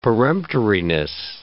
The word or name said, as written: peremptoriness